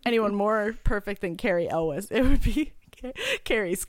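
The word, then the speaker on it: Elwes: The correct pronunciation, according to Carrie himself, is Elvis, but with a W.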